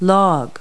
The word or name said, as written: log